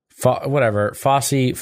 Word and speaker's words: Fauci: I think maybe her end goal is to make Fauci look bad.